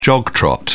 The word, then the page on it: jog trot: British English